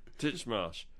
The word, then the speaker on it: Titchmarsh: Monty Don versus Alan Titsmarsh. Marsh. I can't even pronounce his name. Titchmarsh.